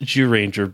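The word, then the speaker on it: Zyuranger: And we're comparing those with the Japanese episodes from it's pronounced Zooranger, but it's spelled Zooranger, so I'm gonna say Zooranger.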